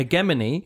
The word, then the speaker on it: hegemony: No, it's stress on the second one. Yeah. Hegemony. – Yes. – And the American pronouncement is hegemony. Hegemony. – So both pronunciations have the emphasis on the second syllable.